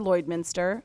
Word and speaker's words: Lloydminster: Um, we are talking with Sarah Rurka and Tasha Hilderman with Home Hardware Lloydminster. Hopefully, I pronounced the the city correctly. Yes, uh-huh.